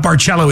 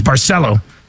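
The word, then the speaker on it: Barceló: It's not Barcelo, it's Barcelo.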